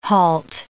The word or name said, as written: halt